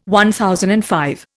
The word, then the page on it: MV: Indian